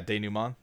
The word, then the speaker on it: denouement: Is that how you pronounce that? – I think I put a little extra stink on it, but, you know, yeah, denouement, yeah.